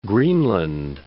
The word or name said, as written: Greenland